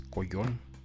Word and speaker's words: collón: Eh, la otra palabra que también está en la, en RAE, en la Real Academia Española, es collón. Eh, collón con doble L y acento en la segunda O.